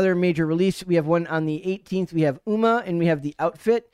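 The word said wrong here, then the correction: Umma: It's not pronounced Uma. It's Oma.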